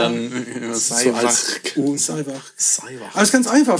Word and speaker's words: Sáimheachd: Also da muss man ein bisschen aufpassen, aber geschrieben wird es S-A-I-M-H-E-A-C-H-D. Auf das erste A ist auch ein Akzent drauf. Die Aussprache ist natürlich alles, was man nicht erwarten würde. Seiwach. – Seiwacht. Seiwacht. Sei mit wach. K, K am Ende. Sei, Sei wach.